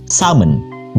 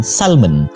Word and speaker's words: salmon: they would traditionally pronounce that word as salmon without the L, but not the case with us especially those who are speaking English as a second language. We would pronounce the L eh, as in salmon, kan?